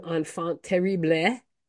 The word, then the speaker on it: enfant terrible: exactly a year ago, we reported that the one-time enfant terrible, am I pronouncing that correctly? – Sure.